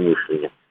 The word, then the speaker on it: мышление: Горбачевское новое мышление, да, как оно правильно произносить, именно мышление.